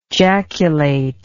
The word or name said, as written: jaculate